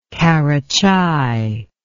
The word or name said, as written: Karachai